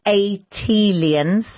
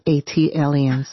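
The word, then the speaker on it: Atlantans: Tayari, just as Jill did for Philly, as an unofficial ambassador for Atlanta, an Atelians. – That is pronounced Atelians. – Ah, thank you. – Like Atlanta alien, Atelian.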